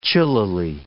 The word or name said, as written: chillily